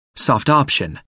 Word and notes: soft option: American English

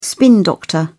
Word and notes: spin doctor: British English

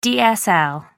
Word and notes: dsl: American English

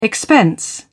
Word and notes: expense: British English

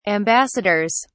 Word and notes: ambassadors: British English

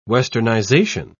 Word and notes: westernization: British English

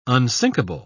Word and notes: unsinkable: British English

